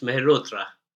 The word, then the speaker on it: Mehrotra: I'll say my last name is Mehrotra.